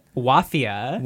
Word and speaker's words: Wafia: Wafia. – Wafia. – Which rhymes with mafia. – Rhymes with mafia, spelled like mafia, but with the W.